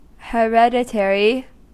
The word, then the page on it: hereditary: American English